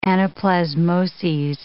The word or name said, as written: anaplasmoses